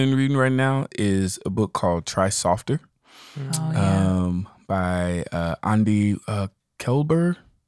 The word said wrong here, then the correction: Kolber: I think it's Kolber. – I'm going to look it up. – It's Kolber.